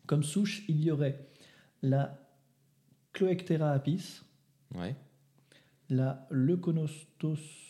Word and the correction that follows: Leuconostoc: Leuconostoc D'accord.